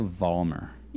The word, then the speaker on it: Vollmar: Olivia Vollmar.